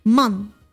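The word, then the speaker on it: man (万): E l'ultimo, 10.000, man. – Man. – Man. Come man. Uomo in inglese si scrive.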